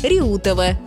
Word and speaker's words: Реутова: Правильно, город Реутов с ударением на «е» и железнодорожная станция Реутова с ударением на «у».